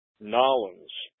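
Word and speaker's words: New Orleans: Norling, or as the folks in the South province pronounce it, Nolens.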